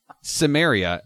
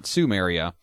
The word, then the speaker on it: Cimmeria: Conan the Barbarian, or Conan O'Brien, depending on which one you ask, is about a guy from Cimmeria, Cimmeria, not Sumeria.